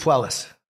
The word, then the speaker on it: Puelles: Claudio, how do you pronounce his last name? – I believe it's Puelles.